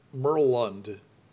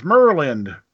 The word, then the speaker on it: Murlynd: I always said Merlund. – Yeah. Hmm. Well, you got to say it with a proper accent. It's Merlind.